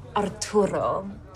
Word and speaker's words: Arturo: So, Arturo. – You don't have to say it like that. It's Arturo, you know? – Arturo.